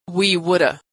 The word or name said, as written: we woulda